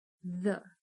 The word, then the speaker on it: the: Só que, diferente de algumas palavras com TH, se você reparar, o the também tem um leve som de Z the the Um erro muito comum na pronúncia do TH é pronunciar como se fosse F.